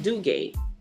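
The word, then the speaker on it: Dugué: But as a kid, like when we learned the pronunciation of our name, we were always told that it was a French name and that it's pronounced Duga.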